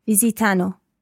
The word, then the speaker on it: visitano: But just watch your accent on the third person plural. Visitano. – Visitano. – It is a very tricky sort of pattern of sounds to get in there.